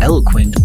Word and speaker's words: ELQNT: Next up is Eloquent by Temudo on V and VAL on SK Black.